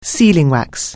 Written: sealing wax

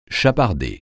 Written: chaparder

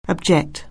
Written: object

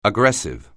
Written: aggressive

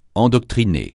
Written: endoctriner

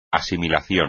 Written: asimilación

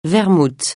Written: vermouth

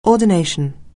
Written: ordination

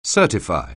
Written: certify

